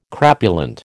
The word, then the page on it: crapulent: American English